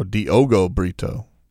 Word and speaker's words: Diogo Brito: Diego Brito. Oh, Diogo Brito.